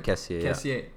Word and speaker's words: Kessié: Kessié, I believe that's how it's pronounced, got a red card. – Kessié. Yeah.